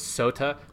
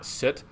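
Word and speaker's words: sote: So it's sota, not soot. Even that, like that sota is not soot, it's sweet. So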